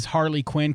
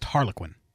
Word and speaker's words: Harlequin: I pronounced the album name as Harley Quinn because that's the name of her character, sure. – But I think the album's just Harlequin.